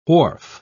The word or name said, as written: wharf